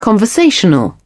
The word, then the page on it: conversational: British English